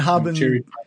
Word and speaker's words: Harbin: Did you say your name is Hardon? – It's Harbin. Harbin.